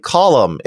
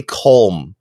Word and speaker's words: Colm: I feel like I gotta tell ye, Colum is pronounced like Colum. It rhymes with Gollum, which is Ricardo's favorite thing.